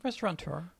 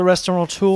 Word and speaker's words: restaurateur: How do you say that? – Restaurateur. – But I hear an N in there. – Well, I mean, I think it's... – It's not spelled with an N.